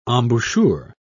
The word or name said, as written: embouchure